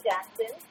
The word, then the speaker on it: Jackson: I don't know the exact LinkedIn is very difficult about their but it's I'm Sasha Jackson, so it's spelled SASHA JACKSON.